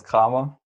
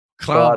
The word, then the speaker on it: Kramer: Well, it's just the German way is Lucas Kramer. Kramer.